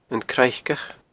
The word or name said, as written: Craiceach